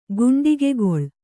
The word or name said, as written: ಗುಂಡಿಗೆಗೊಳ್